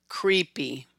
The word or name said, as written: Creepy